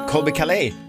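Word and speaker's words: Colbie Caillat: att Colby Calais. Så uttalar man det.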